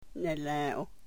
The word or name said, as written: ne-lew